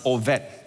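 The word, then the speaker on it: Obed: His name is, you pronounce as Ovet in Hebrew name.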